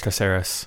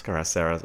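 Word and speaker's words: Cáceres: Moving along, Southampton signs former Juve centre-back Martin Caceres until the end of the season. Is that how you say it? – I think it's Caceres. – Caceres. Okay, all right.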